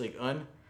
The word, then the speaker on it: Ligue 1: We started this week was English Premier League and French Ligue 1. Gotta say it like that.